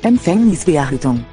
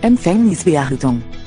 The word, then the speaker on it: Empfängnisverhütung: When a V is in the middle of a word, it sounds like a V. – Empfängnisverhütung. Die Empfängnisverhütung. Die Empfängnisverhütung.